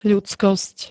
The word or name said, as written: ľudskosť